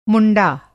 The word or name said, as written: मुंडा